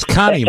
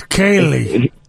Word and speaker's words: Khalil: Coach, you thought it was Connie Mack. – No, it's Kaylee.